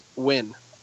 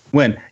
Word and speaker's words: Nguyen: It's just pronounced Win. – Win. Yeah, Win. It's Vietnamese, and it's a very common Vietnamese name.